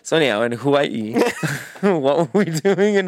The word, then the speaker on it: Hawaii: Hawaii. Am I saying it wrong? – No, you're saying it right, but you just kind of add a "who" in there that I like.